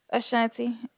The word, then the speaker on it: Ashanti: Ashanti. A-S-H-A-N-T-I.